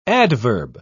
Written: adverb

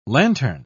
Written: lantern

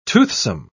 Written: toothsome

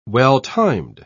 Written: well-timed

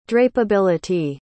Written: drapability

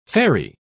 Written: ferry

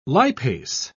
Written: lipase

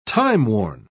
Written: timeworn

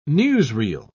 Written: newsreel

